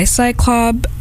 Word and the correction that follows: Cylob: I mispronounced their name. Cylob. I don't know if I said Cylob or Cyclob. I thought I said Cyclob.